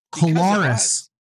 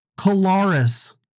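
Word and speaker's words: Collaros: Most everybody has finally figured out how to say the best player in the league's name. – Yeah. – Kolaris. Kolaris. Kolaris. And Kolaris.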